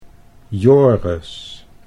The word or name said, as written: Joris